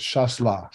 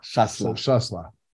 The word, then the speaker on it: Chasselas: Yeah, and, you know, and we just, and I'm like, so how do we pronounce it anyway? And Jeff said, he looked at me and said, there's a pronunciation guide on every page. So we're glad somebody noticed. – So just so you know, if you're looking at wine from Switzerland, it's called Chasselas. – Chasselas.